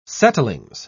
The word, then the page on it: settlings: British English